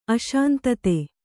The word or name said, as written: ಅಶಾಂತತೆ